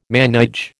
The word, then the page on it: manège: American English